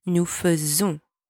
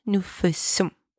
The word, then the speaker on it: faisons: First, you've got to make sure you say nous faisons and not nous faisons. There's only one S here, not two. Nous faisons. Nous faisons. It sounds like a Z.